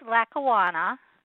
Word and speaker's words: Lackawanna: It's Lackawanna.